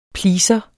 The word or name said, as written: pleaser